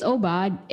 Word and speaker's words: Obad: It's Obad, and sometimes we pronounce it Obed, but, you know, it's just a slip of tongue, but it's really Obad.